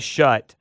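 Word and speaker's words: Schutt: which is a shut.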